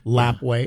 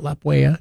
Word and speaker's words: Lapwai: Kamiah, Lapwai. It's not Lapwaya.